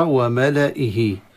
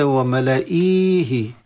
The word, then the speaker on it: وملائه: والدائرة الصغيرة تشير إلى أن ما تحت الدائرة، ما تحتها من الحروف لا تنطق. فتقرأ وملائه وليس وملائيه. وملائه، فالانتباه لذلك.